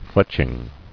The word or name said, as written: Fletching